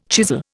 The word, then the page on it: chisel: Indian